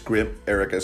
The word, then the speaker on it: Grimerica: So basically Grim and then America, but it's Grim Erica. It's spelled Grimerica and then Outlaw.